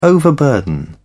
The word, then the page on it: overburden: British English